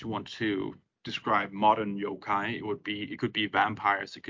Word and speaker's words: yokai: And in general, if you want to be talk about monsters in general, yokai is the way of pronouncing saying that.